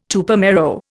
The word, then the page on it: Tupamaro: Indian